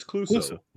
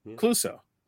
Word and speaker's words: Clouseau: it misleads the public, because if you ask the public who's the Pink Panther, they would say it's Jacques Cousteau. Jacques Clouseau. I'm saying his name wrong. – It's Clouseau. – Clouseau.